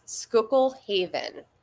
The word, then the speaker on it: Schuylkill Haven: My hometown was, uh, it was Schuylkill Haven, which is, it's spelled really weird like S C H U Y L K I L L Haven.